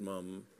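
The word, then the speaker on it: Mapledurham: was Lester Maple Durham, pronounced Mum, the explorer and big game hunter.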